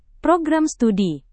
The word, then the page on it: program studi: Indonesian